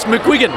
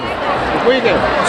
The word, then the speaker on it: McQuiggan: Now, how do I pronounce the name of your wine company? – It's McQuiggan. – McQuiggan. – So, Irish background, hence a little hard to get, but...